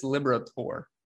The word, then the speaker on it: Liberatore: Yeah, I put it as Baseball Reference to see how you actually pronounce his name. I believe it's Liberatore.